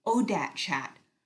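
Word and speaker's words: ODAAT Chat: which is pronounced ODAT chat, O-D-A-A-T, for one day at a time, where her guests share their stories of alcoholism and addiction and how they recovered.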